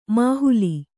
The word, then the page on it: ಮೊಹಲ್ಲಾ: Kannada